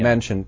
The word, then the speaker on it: Manchin: So he was talking about Manchin. Is that – am I pronouncing that correctly? From Manchin. Yeah. – Manchin. Mm-hmm. Virginia? – Yeah.